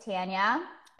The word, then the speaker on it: Tanya: A lot of people will call me Tanya. I was like, it's Tanya. And Latins have a really hard time with it because of the pronunciation. So they go Tanya.